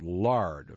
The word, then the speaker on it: Lord: And the note beside it, which I didn't see first, says, pronounced lard, of course, giving praises to the lard.